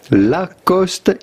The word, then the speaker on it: Lacoste: Lacoste in French, Lacoste.